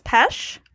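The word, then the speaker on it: Pesce: The Eyes of My Mother from 2016 is our first film, written and directed by Nicholas Pesch. That's correct. That's how you say his name, in case you were wondering, Carly, because I looked it up.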